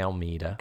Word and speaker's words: Almeida: People say it everywhere, up and down, but it is Americanized, so we say Almeida.